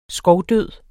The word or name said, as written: skovdød